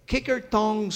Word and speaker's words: Kikkertong: Here's one that I was told to pronounce in Dutch. It's called the Kikkertong Stomp.